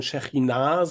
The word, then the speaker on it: Shechinah: Vom Hebräischen würde man Shechinah sagen, also die Betonung hinten auf dem A, aber man kann das sicherlich auch eindeutschen.